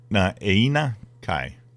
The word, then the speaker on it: Na'aina Kai: Na'aina Kai. – Botanical Garden and Sculpture Park. – N-A-A-I-N-A-K-A-I.